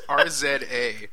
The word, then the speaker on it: RZA: Write down John doesn't know how to pronounce RZA. – Great. It's RZA.